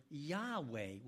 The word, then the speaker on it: Yahweh: And so sometimes in some of the songs we sing, you'll see the word Yahweh with a Y-A-H-W-E-H. That's probably the most correct pronunciation that we can arrive at today.